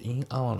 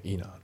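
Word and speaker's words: in an hour: で B の Enter Drawing で抽選会に参加すると、ね、であと発音ですけど、まず In Hour のところがリンキングで Inner ですね。 In Hour じゃなくて Inner、あとその右の Winner のところもリンキングで Winner、 Winner じゃなくて Winner、あとその左下のグッドアットのところが、リンキングが起きてて、アットの T が抜けますね。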